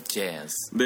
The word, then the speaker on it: jazz: 예, 그거 재즈라고 하는 건 앞에 J 발음도 있지만 뒤에 Z 발음도 있기 때문에 그게 두 개가 같이 똑같이 해버리는 수가 있어요 한국 사람들이. 근데 앞에 J랑 뒤에 Z 발음이 다릅니다. 재즈.